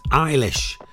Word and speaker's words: Eilish: It's Eilish.